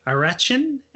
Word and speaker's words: arachin: The other thing that I found really interesting when I was looking into the protein part, the proteins in the peanuts, which is it's pronounced arachin and conarachin, they are the components that people have the severe allergic reactions to.